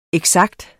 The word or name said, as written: eksakt